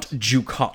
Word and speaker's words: Dukat: Well, if you're going to shit all over him, will you say his name right? – Dukat? Gold Dukat. – Gold Dukat. – Not Dukat. – Dukat. Gold Dukat. – Yes, if you're going to say whatever you're going to say, which is wrong anyway, at least pronounce his name.